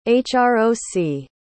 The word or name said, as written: hroc